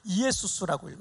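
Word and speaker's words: 이에수스 (Ἰησοῦς): 자, 그런데 이 이름이 신약 시대에 헬라어로 헬라어로는 이 예수아를 어떻게 하냐면 이에수스라고 읽어요. 이에수스.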